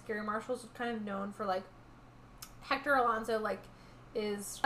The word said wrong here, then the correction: Elizondo: Elizondo. Sorry, I was dropping a syllable.